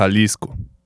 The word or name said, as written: Jalisco